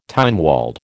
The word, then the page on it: Tynwald: American English